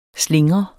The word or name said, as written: slingre